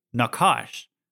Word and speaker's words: nachash: The word serpent is the Hebrew word nakash. Okay, it's spelled N-A-C-H-A-S-H. That's how it's like, I think you call it transliterated into English, nakash.